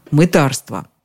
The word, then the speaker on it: мытарство: Обратите внимание на ударение. На «ы» оно приходится только в слове «мытарь». Во всех остальных переходит на «а» – «мытарить», «мытариться», «мытарство».